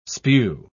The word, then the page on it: spue: British English